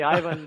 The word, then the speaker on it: Ivan: Ivan it is, which is the proper Croatian pronunciation, I think.